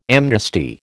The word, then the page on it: amnesty: American English